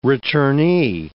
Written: returnee

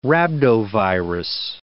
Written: rhabdovirus

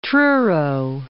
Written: Truro

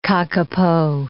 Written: kakapo

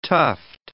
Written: tuft

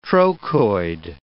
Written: trochoid

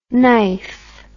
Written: nife

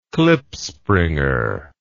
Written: klipspringer

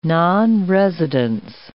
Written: nonresidence

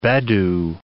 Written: bedu

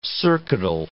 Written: circuital

